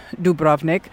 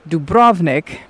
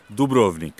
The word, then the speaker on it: Dubrovnik: because in America we often say Dubrovnik, but we put the emphasis on the wrong part of the word, so what's the proper way? – Dubrovnik. – Dubrovnik. So we say the do first.